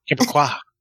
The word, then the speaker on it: Québécois: Québécois. Did I say that right? – Québécois. – Sorry.